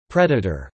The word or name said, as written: predator